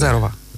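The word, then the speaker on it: Зерова: Я сказал Зарова, а как надо сказать? – Зарова. – А ударение на «э»? Окей, «зеро», извините.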